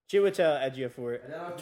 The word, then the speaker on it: Chiwetel Ejiofor: Chiwetel Ejiofor, 12 Years a Slave.